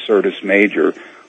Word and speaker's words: Syrtis Major: In reference to Cydonia, it's located in an area called Syrtis Major. That's spelled with an S, Syrtis Major, which is about 3,000 miles away from Cydonia.